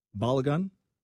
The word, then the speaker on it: Balogun: Balogun? – Sure.